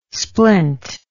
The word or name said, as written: splint